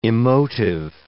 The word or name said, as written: emotive